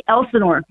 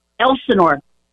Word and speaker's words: Elsinore: It's called Lake Elsinore.